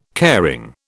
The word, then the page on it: caring: British English